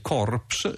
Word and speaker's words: corpse: Notate che ad esempio anche oggi in inglese corpse, scritto in questo modo anche se la P è, è muta e non si pronuncia, e in realtà corpse significa semplicemente cadavere.